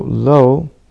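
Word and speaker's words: lo (לא): It's important to point out that we explain this verse is written differently than it's pronounced. So, lo, with an aleph, is the way that we pronounce it.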